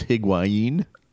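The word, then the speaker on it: Higuaín: I'm afraid to pronounce it. I believe it's Higuain. – Correct. – Heard it several different ways.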